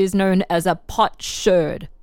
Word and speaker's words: sherd: That, and I'm not saying that funny, that's not my accent, it's spelled S-H-E-R-D. Whereas if you find a piece of a bottle, a glass bottle, that's known as a shard, S-H-A-R-D.